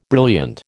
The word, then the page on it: brilliant: American English